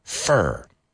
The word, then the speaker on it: fir: F-U-R. Fur. Right? And the other one is abeto, which is F-I-R. And how do you pronounce that one? Fur. Do they sound the same? Absolutely.